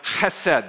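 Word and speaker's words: chesed (חֶסֶד): And if you want to say it right, you really do kind of have to clear your throat. So if you want to practice, just, you know, don't spit on the person in front of you, please. But chesed, that's how you say it.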